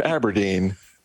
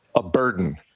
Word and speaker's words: abrdn: Instead of Standard Life Aberdeen, they came up with a single word, which is spelled A-B-R-D-N. Again, Standard Life Aberdeen would now like you to call it Aberdeen. They're telling us A-B-R-D-N is pronounced Aberdeen and not, as I first looked at it, as a burden.